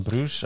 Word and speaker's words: Bruges: altså Bruges vel den franske uttalen.